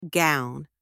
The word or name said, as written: gown